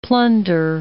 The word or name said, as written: plunder